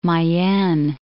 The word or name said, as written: Mayenne